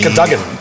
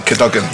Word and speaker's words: Cadogan: We're also recording this episode in the recently refurbished Cadogan Arms, and we'll be catching up with MD... – Isn't it Cadogan? – Cadogan, yeah.